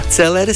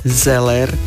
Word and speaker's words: zeler: Celer si dáme, alebo zeler si dáme. Viacerí z nás zrejme používajú v bežnej reči, že celer a celerová polievka, celerová nátierka, ale správne po slovensky je zeler a zelerový, takže mali by sme konzumovať zeler, je zdravý a zelerová nátierka má takisto všelijaké dobré účinky.